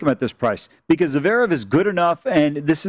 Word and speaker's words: Zverev: How do you say the name? – Zverev.